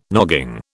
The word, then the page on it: nogging: British English